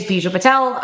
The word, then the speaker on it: Bijal Patel: Well, first, let's clarify how to say my name since most people butcher it. So my name is Bijal Patel.